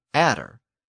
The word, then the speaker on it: adder: The Old English version of the word was nadra, and in some modern dialects of Northern England, it's still pronounced as natter. So if you had one natter, you had a natter. But over time, people became confused, and they thought that it was an adder.